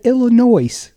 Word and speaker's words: Illinois: And the poor guy, he said Illinois. And he's in front of his home crowd there at the Asheville Chamber of Commerce. And you're like, the S is silent.